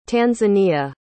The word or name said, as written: tanzania